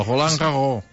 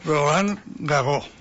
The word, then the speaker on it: Roland Garros: El Roland Garros, que pronunciat en francès, com fa això? A veure, com fa això? – Roland Garros. – Roland Garros, si fa no fa.